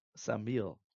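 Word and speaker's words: Samael: We refer to him usually as the Samech Mem, which is basically pronounced like the English word Sam. Sam Samiel is his name.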